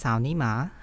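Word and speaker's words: sao ni ma (草泥马): And so the grass mud horse in Chinese is pronounced sao ni ma, and so literally grass mud horse.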